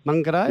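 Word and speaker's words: Māngere: Is it Mangere? – Mangere. – Yeah, Mangere East.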